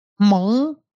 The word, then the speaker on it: Hmong: And there's also a very large, I'm going to say this incorrectly, Hmong. – Yeah. – But it starts with an H. – Yes.